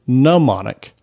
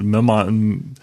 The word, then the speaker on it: mnemonic: It's not mnemonic. It's actually mnemonic. – Right, mnemonic. The M is silent.